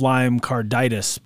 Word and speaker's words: Lyme carditis: I've never heard of Lyme carditis before.